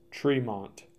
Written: Treemont

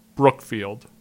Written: Brookfield

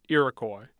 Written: iroquois

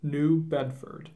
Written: New Bedford

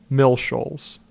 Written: Mill Shoals

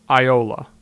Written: Iola

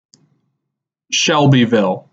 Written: Shelbyville

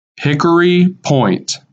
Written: Hickory Point

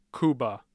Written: Cuba

